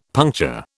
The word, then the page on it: puncture: British English